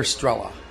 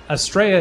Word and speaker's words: Estrella: Do you pronounce that Estrella or Estrella? – Estrella is technically the proper pronunciation for our airport, Estrella Sailport. It's a Spanish word that means star. But most people in our country pronounce it Estrella, which is totally fine with us, too.